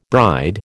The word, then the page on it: bride: American English